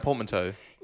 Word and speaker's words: portmanteau: Portmanteau.